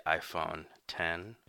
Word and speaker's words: iPhone X: Plus the iPhone X isn't very good. Yeah, I said it. – I believe it's pronounced the iPhone X.